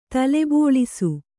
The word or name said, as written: ತಲೆಬೋಳಿಸು